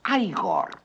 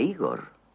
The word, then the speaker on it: Igor: No, se pronuncia Igor. A mí me dijeron que era Igor.